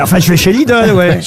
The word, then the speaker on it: Lidl: Il n'y a pas de O, c'est l'idole. – Oui, Lidl.